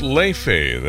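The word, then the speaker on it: Laufey: Her name is Leife, that's how it's pronounced.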